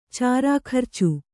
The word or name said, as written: ಚಾರಾಖರ್ಚು